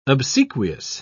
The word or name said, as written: obsequious